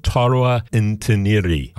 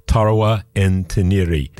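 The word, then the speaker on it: Tarwa N-Tiniri: And that's a track called Zaman, and it is the title track of a brand new album out from a Moroccan band, Tarwa Ntaniri. I will spell that for you again. That's T-A-R-W-A, Tarwa, and then N-hyphen T-I-N-I-R-I, Tarwa Ntaniri.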